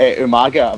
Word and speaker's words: Umaga: I need to start saying the name properly again. Uh, Umaga.